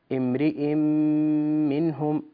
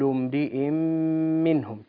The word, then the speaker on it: امرئ منهم: ثانيا أيطمع كل امرئ منهم، امرئ منهم هكذا ينطق الإدغام. أنت تقول كل امرئ منهم مثل الإخفاء، تجعل فرجة بين الشفتين وهذا لا يصح، الحكم إدغام. كل امرئ منهم تنطق هكذا.